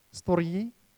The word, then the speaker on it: storge: And then something that I learned in the week, which is always fantastic, storge or storge, how do you pronounce it? Storgi, there we go, which speaks about the love between family members.